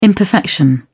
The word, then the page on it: imperfection: British English